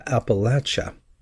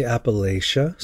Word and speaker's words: Appalachia: Let's turn to one of the greatest mysteries in these United States of America, how to properly pronounce the name Appalachia. Some say Appalachia, some say Appalachia. Those who come from that part of our country settle the matter. They have a saying, if you're missaying Appalachia, we'll throw an apple at ya.